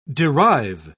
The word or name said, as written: derive